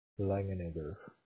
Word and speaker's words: Langenegger: And then, Odo, I have your first name is O-T-T-O, is that correct? – That's right. – And then what's your last name? – Langenegger. – Can you spell that for me? – L-A-N-G-E-N-E. L-A-N-G-E-N-E-G-G-E-R.